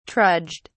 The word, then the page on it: trudged: British English